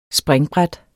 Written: springbræt

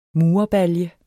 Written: murerbalje

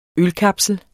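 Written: ØLKAPSLER

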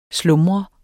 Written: SLUMREDES

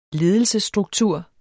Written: ledelsesstruktur

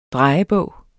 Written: drejebog

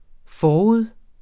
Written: forud

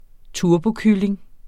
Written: turbokylling

